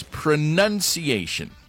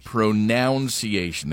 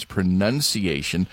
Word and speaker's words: pronunciation: It's pronunciation, not pronunciation. There's no noun in there. It's pronunciation. Also, it's et cetera.